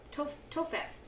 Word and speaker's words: Topheth: How do you say that? Topheth. P and an H becomes an F sound, right? – In English.